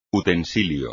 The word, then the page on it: utensilio: espagnol